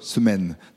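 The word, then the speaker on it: Semène: Et ça s'écrit S-E-M-E, accent grave N-E, Semaine, dans ce cas-là.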